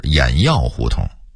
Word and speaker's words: 偃月胡同: 此外还有劈柴胡同的读音应该是劈柴胡同，西河沿的读音应该是西河沿，焦子胡同的读音应该是轿子胡同，原来的胡同名是轿子胡同，偃月胡同的读音应该是偃药胡同，